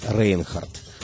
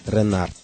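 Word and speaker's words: Reinhardt: Пожалуй, единственной джазовой суперзвездой тех лет, родом из Старого Света, был французский цыганский гитарист, которого мы традиционно называем Джанго Рейнхардт. На самом деле его фамилия произносится примерно как Ренарт.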